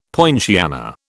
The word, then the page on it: poinciana: British English